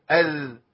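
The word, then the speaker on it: إذ: الدال على نقطه تنطق هكذا، اذ طرف لساني مع طرف الثنائي العليا.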